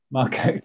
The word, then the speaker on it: Marcotte: Marcotte. Yeah, Eloise Marcotte. But if you can roll your R's, then you've got to do that at the end.